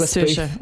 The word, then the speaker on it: Saoirse: It is Saoirse.